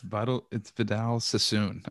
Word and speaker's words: Vidal Sassoon: I use Vital Sassin because it sounds like assassin. So I said, I want that guy's products, Vital Sassin. – It's Vidal Sassoon. I think that's the way you pronounce it.